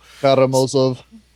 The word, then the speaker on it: Karamazov: Karamazov. – So you didn't roll it? – I did, a little bit.